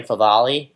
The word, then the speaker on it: Favale: I am at Dan Favale. It is F-A-V-A-L-E.